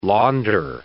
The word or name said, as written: launder